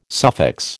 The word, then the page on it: suffix: American English